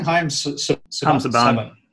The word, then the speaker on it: Haim Saban: Haim Saban.